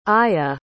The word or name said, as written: aya